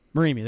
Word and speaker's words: Morimi: How do you pronounce his name? – Morimi, there we go.